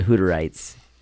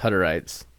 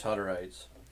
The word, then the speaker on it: Hutterites: And it might be pronounced Hutterites. – I'm pretty sure it's Hutterites. – Jeff, we've been saying it wrong this whole time and you didn't say anything.